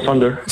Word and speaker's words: Sonder: D'abord, comment, comment on le prononce euh, en, français? Est-ce qu'il y a une prononciation en français? Sunder, Sunder? Ouais, Thunder, euh, ça, se prononce Thunder. Thunder, c'était correct.